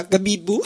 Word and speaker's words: Gabibbo: Dunque, Gabibbo o Gabibbu, pronuncia Gabibbu. È